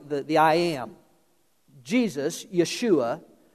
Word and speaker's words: Yeshua: The, the Jews would have said, uh, they would have said Yeshua. When they wrote the New Testament in Greek, it was Jesus. In English, as a hard J, it would be Joshua, Yeshua, Joshua.